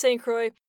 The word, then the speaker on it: St. Croix: We know, French speakers at home, that the French pronunciation is St. Croix. We've been told to anglicize it. For the purposes of today's show, it's pronounced St. Croix.